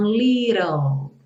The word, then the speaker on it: little: E aí desse rã, a gente vai para o ã, que é o som do L, e o E final não é pronunciado. Então, little, little.